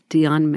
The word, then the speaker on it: Dion: That's a normal D-I-O-N? – Name. Yeah, Dion. – Like Celine Dion. – Correct. – So the name is Dion McGregor, and he... – I actually really like that name, Dion McGregor.